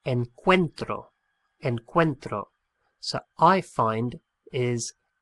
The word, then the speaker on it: encuentro: It's an irregular verb, and the stress of the pronunciation on that first O splits the O into a U and an E, and we end up with encuentro. E-N-C-U-E-N-T-R-O.